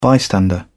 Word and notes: bystander: British English

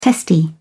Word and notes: testy: British English

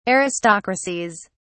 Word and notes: aristocracies: British English

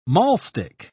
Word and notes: mahlstick: British English

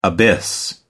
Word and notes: abyss: American English

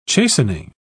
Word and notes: chastening: British English

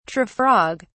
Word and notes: tree-frog: British English

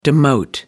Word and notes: demote: American English

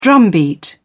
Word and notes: drumbeat: British English

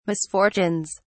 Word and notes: misfortunes: British English

